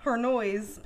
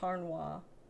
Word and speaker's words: Harnois: Harnels. That's what his last name looks like. Harnois. – Harnois, but it's pronounced Harnois.